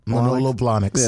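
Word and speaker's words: Manolo Blahnik: Can I get the Malonos? – Exactly. – It's like me and... – Am I saying that right? Manolo, how does this choose? Manola, I forgot. – Manolo Blahniks.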